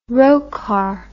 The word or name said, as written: Rocard